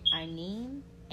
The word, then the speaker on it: Aine: And then I guess it's pronounced Aine, A-I-N-E, and Coventina is literally just like the word coven and then Tina.